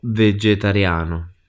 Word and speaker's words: vegetariano: Sono vegetariano. – And here, similar to in English, the spelling, we've got G plus E. So we've got this nice soft J sound in the middle.